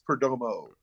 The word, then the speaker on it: Perdomo: Just change the last O to an O, so it's Perdomo.